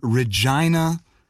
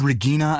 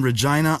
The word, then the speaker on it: Regina: Anyways, this person is writing with greetings from Regina, Saskatchewan in Canada. – Which I had mispronounced. – You said Regina, I think. I was so embarrassed because I knew the correct pronunciation, and I shut my yapper.